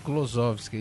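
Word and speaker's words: Kozlowski: Que é a nossa querida Glenda Klosowski. Falei certo? Nem sei se eu falei certo. – Klosowski, é isso aí.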